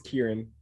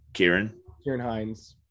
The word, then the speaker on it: Ciarán Hinds: From Belfast, I already mentioned Ciarán Hinds. I'm also butchering that name, I'm sure. – I think it's Kieran. Ciarán Hinds.